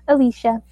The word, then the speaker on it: Alesha: Alicia.